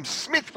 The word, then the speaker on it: Psmyth: I am Smith with a silent P.